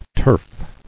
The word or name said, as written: Turfing